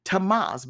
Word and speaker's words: Tammuz: The holy place was reserved for priests, and it was here that the women were worshiping the pagan god Tammuz, by the way, spelled T-A-M-M-U-Z, pronounced Tammuz, who was associated with immoral and impure rites.